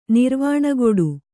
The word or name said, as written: ನಿರ್ವಾಣಗೊಡು